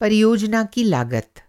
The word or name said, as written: लागत